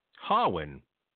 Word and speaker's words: Ha-Nguyen: Hawin. – Yes. – So uh like when like I'm winning.